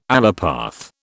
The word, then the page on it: allopath: British English